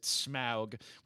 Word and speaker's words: Smaug: The Hobbit, the Desolation of Smaug is how I've always pronounced the dragon's name, although I believe Peter Jackson pronounces it Smaug, which I love.